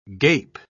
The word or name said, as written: gape